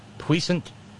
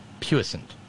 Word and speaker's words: puissant: Oh, okay, both pronunciations are okay. Puissant, puissant. I usually use the first pronunciation, which is closer to the French puissant.